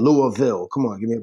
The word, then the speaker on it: Louisville: How do you say it? Can you say it for us? – Louisville. – Now, you're from Philadelphia, so you don't really have that Southern accent. No, so, but I screw it up all the time and say Louisville, and I catch it from the locals around here, and they correct me, and it's annoying.